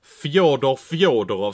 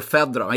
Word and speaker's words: Fedorov: Fjodorov, ska man säga så om honom? Är det så man uttalar det? – Fjodor Fedorov. Jag sa alltid Fedorov om Sergej Fedorov.